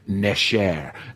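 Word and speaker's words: nesher (נשר): The Hebrew word for eagles is the Hebrew word nesher.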